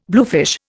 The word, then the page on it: bluefish: Indian